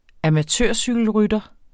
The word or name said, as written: amatørcykelrytter